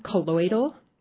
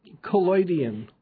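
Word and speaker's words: collodion: And the photographs are called, am I pronouncing this right, colloidal? – Colloidian.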